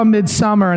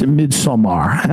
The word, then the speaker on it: Midsommar: I said, oh, I saw Midsommar, and they said, it's Midsommar.